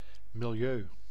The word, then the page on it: milieu: /mɪlˈjøː/